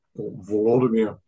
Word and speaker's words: Volodymyr: Yes, it's Vladimir, or Volodymyr, if we do the Ukrainian pronunciation, whose statue is still there in Kyiv.